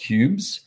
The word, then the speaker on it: Cubzh: If you're watching, you'll see it's spelled C-U-B-Z-H or H. So it's pronounced Cubes. They're very cool. They've got the B-Z-H URL. So it's C-U.B-Z-H, which I like stuff like that.